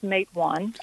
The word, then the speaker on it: Matewan: I can, and it's actually pronounced Matewan. I think there's a Mattawan, New York, and a lot of people get it confused.